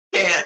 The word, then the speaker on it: Cannes: If anyone's in Cannes, this is how I remember how to pronounce it, like a can of corn. – Yeah, it's like Cannes.